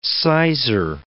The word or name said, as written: sizer